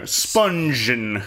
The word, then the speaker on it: Spungen: Spungen.